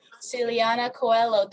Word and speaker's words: Siliana Coelho: Siliana Coelho.